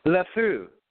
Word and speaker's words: LeFou: You know Lefou? – Lefou.